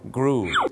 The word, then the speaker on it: grew: มันจะต้องออกเสียงสระอูครับสระอูถูกต้องครับอย่างเช่น grow ก็ต้องกลายเป็น grew แต่พี่น้องชาวไ